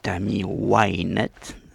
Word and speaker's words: Wynette: Tammy Wynette, n'est-ce pas Pour prononcer vraiment à l'américaine.